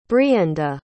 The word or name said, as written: brienda